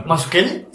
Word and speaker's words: Mazzucchelli: Okay. Mazzucchelli, ¿Mazzucchelli? – Sí, así se pronuncia.